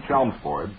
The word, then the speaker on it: Chelmsford: As a lifelong resident of the town of Chelmsford, I feel it is my duty to inform you that the name Chelmsford is pronounced exactly as it is spelled. The L is not silent.